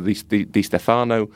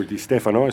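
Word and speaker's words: Di Stéfano: and most notably Alfredo Di Stefano from River Plate. – Or Di Stefano, as some of us call him. – If you're pronouncing it incorrectly, yes.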